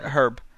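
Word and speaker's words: Hryb: So before you rant, we're going to just get a little word from Microsoft's Larry Hrib, I guess. I don't know. – Herb. – Herb. – It is. I'm pretty sure it's pronounced Herb.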